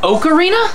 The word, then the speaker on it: Ocarina: It's pronounced Ocarina? – Yeah. I thought it was Okra of Time.